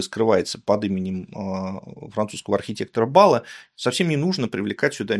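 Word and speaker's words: Balat: Его зовут Альфонс Бала с ударением на последний слог.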